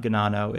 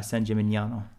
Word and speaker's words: San Gimignano: So it's not Ganano. It's the G and the N make a Nya, as in like gnocchi, mm-hmm. San Gimignano.